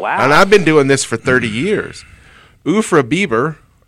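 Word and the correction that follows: Urfa biber: or is it Biber? – I pronounce it as Urfa Bieber, but okay. It's not related to Justin Bieber.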